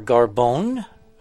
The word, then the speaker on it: Garbon: My guest this morning is Joel Garbon, or Garbone. – Garbon, like carbon. – Rhymes with carbon.